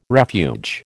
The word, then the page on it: refuge: American English